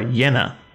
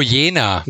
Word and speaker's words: Jena: Is the city pronounced Jena or Jena? – Oh, Jena.